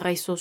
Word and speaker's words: réseau: Estaré utilizando el artículo definido en plural, que es el ES, y redes se escribe de la siguiente manera: R, E, pero tendrá acento agudo, es decir, que mide menos de 90 grados, es el mismo acento ortográfico del español y esto le permite a la E sonar como una E normal en español, por eso sería R, E, S, E, A, U, X, Réseau.